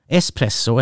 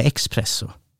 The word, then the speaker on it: espresso: Espresso eller expresso? Ja, espresso. Espresso. Ja, det är inget ex.